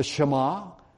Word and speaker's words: Shema: It's spelled S-H-E-M-A, but it's pronounced Shema.